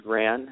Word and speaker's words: Gran: Okay, so my first name is Stephen, and my last name is Gran,